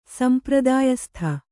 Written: ಸಂಪ್ರದಾಯಸ್ಥ

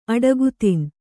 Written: ಅಡಗುತಿನ್

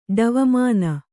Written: ಡವಮಾನ

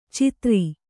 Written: ಚಿತ್ತಾರಿ

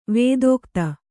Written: ವಿದಗ್ಧ

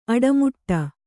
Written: ಅಡಿಮುಡಿ

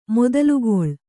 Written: ಮೊದಲುಗೊಳ್

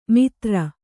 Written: ಮಿತ್ರ

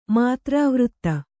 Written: ಮಾತ್ರಾವೃತ್ತ

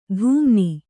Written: ಧೂಮ್ನಿ

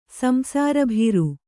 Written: ಸಂಸಾರಭೀರು

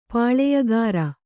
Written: ಪುಲಿಯುಗುರ್

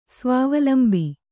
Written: ಸ್ವಾವಲಂಬಿ

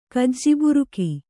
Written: ಕಜ್ಜಿಬುರುಕಿ

